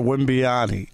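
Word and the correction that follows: Wembanyama: You got to learn how to say it, Wimbanyama.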